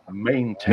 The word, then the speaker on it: maintain: Then I pronounce the word maintain. Mali ang pronunciation ko. You don't say it maintain. You say maintain.